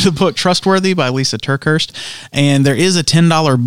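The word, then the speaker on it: TerKeurst: How do you say that name? – Turkhurst. Yeah. Lisa Turkhurst.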